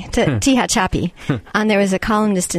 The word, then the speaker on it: Tehachapi: The city is called Tehachapi.